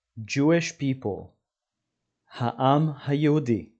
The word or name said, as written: Jewish people